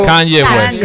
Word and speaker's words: Kanye West: Kanye West.